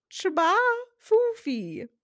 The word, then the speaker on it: J'ba FoFi: It is spelled J-B-A-F-O-F-I, but apparently it is pronounced Chaba FoFi.